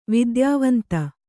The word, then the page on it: ವಿದ್ಯಾವಂತೆ: vidyāvante